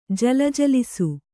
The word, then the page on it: ಜಲಜಲಿಸು: jalajalisu